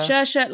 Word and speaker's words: Saoirse: Have I said that right? Saoirse. Saoirse. Saoirse. – Saoirse, like inertia.